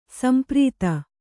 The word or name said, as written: ಸಂಪ್ರೀತ